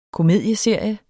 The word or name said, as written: KOMEDIESERIE